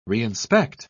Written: reinspect

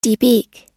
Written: debeak

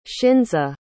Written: shinza

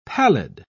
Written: pallid

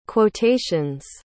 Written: quotations

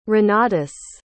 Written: renatus

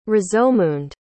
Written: rozomund

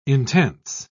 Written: intense

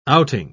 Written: outing

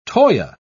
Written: toea

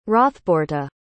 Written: hrothbeorhta

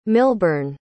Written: milbyrne

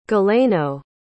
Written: galeno

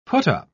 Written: put-up

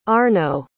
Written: Arno

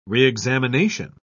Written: reexamination

